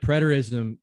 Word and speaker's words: preterism: Yes, preterism is how you'd say it.